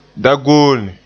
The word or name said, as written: dagoone